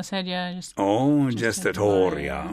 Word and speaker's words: sedia gestatoria: He was carried in on the sedia? – Gestatoria. – How do you say that? – Well, the sedia gestatoria is gestatoria.